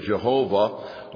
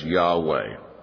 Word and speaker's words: Yahweh: That's why sometimes in English it will be pronounced Jehovah with different vowels placed in different places within the four consonants that make up the Hebrew word Yahweh. So the old English pronunciation is the familiar Jehovah, but most modern scholars pronounce this personal name of God as Yahweh.